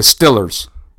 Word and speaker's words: Steelers: And like they call them out there in Pittsburgh, the Stillers. Not the Steelers. They call them the Stillers. Stillers. I spent some extensive time in that area, and that's how they pronounce it.